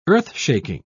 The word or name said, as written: earthshaking